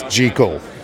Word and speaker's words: Jekyll: And yes, that's how you pronounce it. It's Jekyll. It's not Jekyll. Jekyll is a magpie.